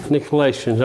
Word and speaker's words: Nicolaitans: Is it Nickelodeon? Is that the way you pronounce it? – The churches? – It's in the sixth verse. – The Nicolaitans. – Oh, well, that's why I couldn't do it. I was calling it wrong, I guess.